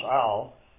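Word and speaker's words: al (על): The word in the Hebrew text is it's pronounced al. It's a two-letter word.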